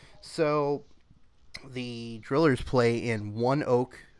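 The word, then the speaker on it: ONEOK: All one word, but it's pronounced One Oak.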